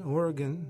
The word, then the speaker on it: Oregon: Gun, Oregon. That's how they say it, not gone.